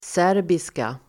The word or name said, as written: serbiska